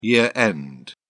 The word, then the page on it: year-end: British English